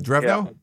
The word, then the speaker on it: Drevno: You know, the entire coaching staff, more or less, except for uh, the new coach who just came in from uh, Michigan, Dribo. Drevno, is that the way you pronounce it? – With uh, Drevno? – Yeah.